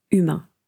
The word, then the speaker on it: humain: Ils continuent de se battre pour obtenir la fin de la dictature et pour obtenir la liberté et les droits fondamentaux humains. Donc attention, en anglais on dit « human », mais en français c'est « humain ». On ne prononce pas le H.